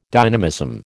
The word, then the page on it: dynamism: American English